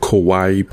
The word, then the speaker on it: Kawaii: Now with Bastet, her skin that I love to play with her is it's called Kawaii Pop. It's like the word Hawaii, but spelled with a K, because it's like K-pop Hawaii.